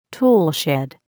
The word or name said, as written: toolshed